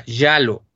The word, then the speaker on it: giallo: Hay una cosa importante, es un género que en italiano se llama, a ver si lo pronuncio bien, hasta estuve buscando la pronunciación exacta, giallo. Se escribe Giallo y quiere decir amarillo.